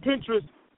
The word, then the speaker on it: Pinterest: Pinterest. – But y'all know P-I-N, say it for me.